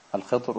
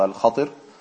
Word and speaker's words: Al-Khidr: Ja, Al-Khidr oder Al-Khatir. Zwei Aussprachen.